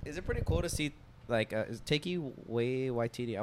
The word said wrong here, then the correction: Taika Waititi: I want to say it right. Taiki Waititi? – Taiki Waititi. – Taika Waititi? – Taika Waititi.